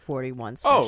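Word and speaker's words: .41 Special: And it might have been helpful if you knew that there was a dot in front of the 41, but they just pronounce it 41 special.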